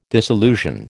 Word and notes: disillusion: American English

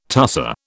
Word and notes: tusser: British English

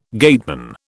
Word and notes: gateman: British English

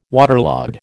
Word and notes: waterlogged: American English

